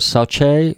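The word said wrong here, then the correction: Saoutchik: Well, that was a French coach builder as well, wasn't it? – Sauchique. Yes.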